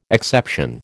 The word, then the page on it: exception: American English